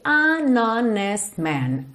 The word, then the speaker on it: honest: I'll be an honest man, an honest man. Essa palavra honest tem uma curiosidade, que esse H é mudo, o que não é comum em inglês, certo?